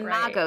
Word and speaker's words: Imago: It was called Imago. Am I saying that right? – You are saying it Imago. – Right. – So for those who don't know what I'm talking about, it's spelled I-M, like mom, A-G-O.